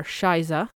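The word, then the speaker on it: Scheiße: I do have two words that I learned while watching this show, and they are shiza,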